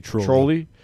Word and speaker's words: Trolli: Number 2, Trolley. I'm not sure how to say it. T-R-O-L-L-I. – I do Trolley.